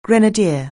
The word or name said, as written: grenadier